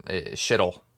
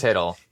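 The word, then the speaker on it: Chytil: No, I know that his like, I always pronounce his name Shittle. – Yeah, I do too. But it's just easier. – It's Hiddle. – Hiddle. It's just a silent letter.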